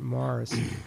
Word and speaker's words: Maurice: So is it spelled M-A-U-R-I-C-E? – It's spelled as if it's pronounced Maurice, but of course the British pronounce it. – Morris. – Strange. – Morris. – They're idiosyncratic in pronunciations. They pronounce it Morris.